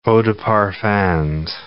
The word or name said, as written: eau de parfums